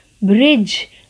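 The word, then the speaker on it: bridge: ब्रिज B R I D G E ब्रिज D silent है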